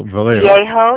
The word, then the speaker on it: Viejo: That's Post Office Box 3000, Mission Vallejo. – Viejo, yeah, Viejo. It's V-I-E-J-O. It's not pronounced the way it is.